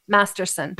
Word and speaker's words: Masterson: But you sound like you're putting an R in there for no reason. – Master. I am Australian, fool. – Can you say it like you're from California? – Masterson.